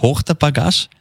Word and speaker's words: Hochter Bagage: Das wünschen dir dein Mann Andi und die Hochter Bagage Nicole mit Anhang. Ich weiß nicht genau, ob ich es richtig ausgesprochen habe. Hochter Bagage. – Das passt schon.